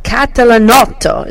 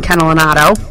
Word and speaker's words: Catalanotto: Frank Catalanotto is how you say that in Italian, but people call him Frank Catalanotto.